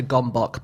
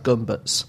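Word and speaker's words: Gömböc: It's spelt like a gombok, but I think it's pronounced more like gumbuts.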